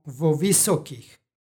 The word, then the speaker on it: vo visokih: psalmu u 4. stihu pročitali smo vo visokih. Predlog i reč počinju istim vokalom, radi lakšeg izgovora dodajemo izgovorno o između njih.